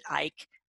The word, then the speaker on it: Icke: Last name is Icke, and a lot of people don't know how to pronounce his name, but I've seen many of his videos. He pronounces it Icke.